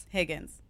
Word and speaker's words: Higgins: Is it Higgins? – Higgins. – You know what? There's an H there. You can make the sound if you want to. – Is it a silent H? – Some people say it's a silent H.